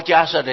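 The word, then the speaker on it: 迦叶: 到了人寿两万岁的时候，第三尊佛出现时间，迦摄佛，跟摩诃迦摄的迦摄相同的迦摄佛。那个夜不念夜，念摄，慈悲摄受的摄，念成同音啊。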